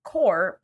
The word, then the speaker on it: corps: Fun fact, it is pronounced core, but spelled C-O-R-P-S because it comes from the Latin term corpus, meaning body. And then it beep, bop, booped on over to France, where they kept the vibe, but dropped the pronunciation of the letter P, thus core.